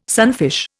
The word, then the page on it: sunfish: Indian